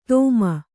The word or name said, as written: ತೋಮ